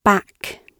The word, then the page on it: back: British English